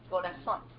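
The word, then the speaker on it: corazón: So I'm going to share part of his definition of the Greek word cardia, which is the word heart, or corazon. And is that how you say it? – Yes, corazon.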